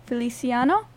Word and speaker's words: Feliciano: Feliciano.